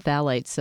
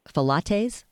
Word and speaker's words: phthalates: You mentioned phthalates a few minutes ago. Just a funny anecdote. Someone said the pH is silent, and someone thought it was either Falates, which they thought sounded pretty yummy, or Pilates.